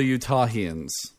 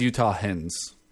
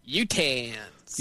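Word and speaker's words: Utahn: I braced for the immediate idiocracy of my fellow Utahians. Is that how you say it? – I guess so. – I think it's Utahans. – I would say Utahans. – Yeah, Utahans. You must understand,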